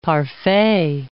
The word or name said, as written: parfait